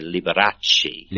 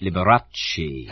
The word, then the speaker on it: Liberace: It's almost the Italian pronunciation. In Italian, you roll the R a little bit. You say Liberace. – Liberace.